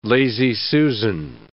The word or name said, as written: lazy Susan